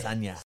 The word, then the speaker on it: Adesanya: Adesanya.